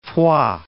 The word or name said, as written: Foix